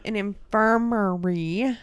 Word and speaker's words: infirmary: That there is a nursing home on the hill right there, and it used to be an infirmatory. – Mm-hmm. – Did I say that right? Infirmatory. You're getting to me. – I'll blame it on me. – Yeah, I am. An infirmary.